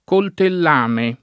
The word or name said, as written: coltellame